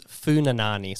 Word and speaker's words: Pfunanane: So it's Pfunanane. So P-F-U. N-A-N-A. So we used to call it, to help people spell it, Pfunanane. So it's to do the Pfunanane, but it's just Pfunanane.